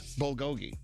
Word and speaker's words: bulgogi: Is it bulgogi? Is that how you say it? – I don't know. – I think it is. Bulgogi.